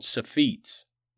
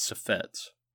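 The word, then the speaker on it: Suffetes: Second, the supreme leaders of the Carthaginian state were called Sephetes, not Sephets.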